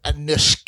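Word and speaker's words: Anouska: You're not pronouncing right. Anushka.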